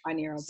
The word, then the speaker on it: binaural: is it binaural beats? Is that how you pronounce it? – Binaural beats.